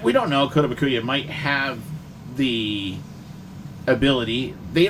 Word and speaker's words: Kotobukiya: You have behind me the Kaitabashi statue of the first set. – Kotobukiya. – Yeah, whatever. Of the first set of armor. Can you give me that? I don't know how to pronounce the name. – Kotobukiya. – Kotobukiya. Okay.